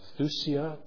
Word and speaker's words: thusia: Vines is helpful in his expository dictionary of the New Testament words, and he writes sacrifice, with the Greek word thusia, T-H-U-S-I-A, the English, is I'm pronouncing that correct, Chris? – I have.